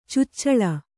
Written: ಚೊಚ್ಚಿಲು

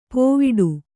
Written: ಪೋವಿಡು

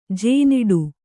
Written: ಜೇನಿಡು

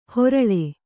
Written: ಹೊರಳೆ

